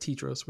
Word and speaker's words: Tedros: The first time I said Tedros, which is wrong. So it's Tedros. Tedros. And I looked up the name. So the name Tedros means gift from God or gift of God.